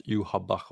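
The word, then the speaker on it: Yhwach: Fram tills nu när deras ledare Juhabach De skrivs Yvach mm. men uttalas Juhabach av någon anledning.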